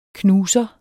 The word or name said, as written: knuser